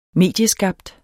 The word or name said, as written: medieskabt